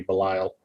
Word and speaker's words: Belial: It should absolutely be Belial, because, you know, it's a reference to a demonic entity in a lot of pop culture things. – Yes. – So I don't know why they chose to pronounce it differently.